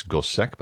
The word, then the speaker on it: Gossec: Last name is spelled G-O-S-S-E-C. One might think it's Gosset, but he apparently pronounced it Gosset.